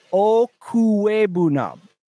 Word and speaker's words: Okwuegbunam: So it's Albert Chumequeca, OKWUEGBUNAM. Okuebunam. Albert Chumequeca Okuebunam.